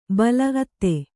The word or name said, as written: ಅತ್ತೆ